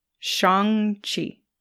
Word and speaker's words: Shang-Chi: It's actually like Sean with a G at the end. Chi. – Shang-Chi.